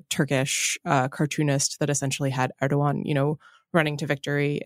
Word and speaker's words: Erdoğan: which is a great victory for everyone who didn't want people to use the hard G, because the alternative was that he was Erdogan, which would have been, of course, inaccurate.